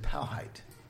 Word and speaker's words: Powhite: P-O-W. – Powhite. I didn't say spell it like a rapper. V-E-R-S. Sachi. P-O-W-H-I-T-E. – Okay. – Wow. White. – No. – P-O white.